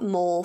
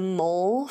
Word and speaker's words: moll: And something I learned in researching this case, when you're a mole for the underworld, it's spelled M-O-L-L, not M-O-L-E. – But it's still like a mole, not mall? – I think so.